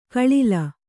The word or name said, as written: ಗಳಲೆ